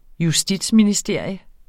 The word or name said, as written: justitsministerium